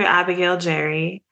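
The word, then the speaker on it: Abigail Jerri: Yes, it is under Abigail Jerry.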